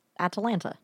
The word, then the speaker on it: Atalanta: Yes, it's spelled exactly like Atlanta with an A in between the T and the L. And Atalanta.